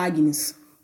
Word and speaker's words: Agnes: Eu sou a Agnes, o G é mudo, eu não.